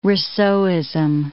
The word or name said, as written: Rousseauism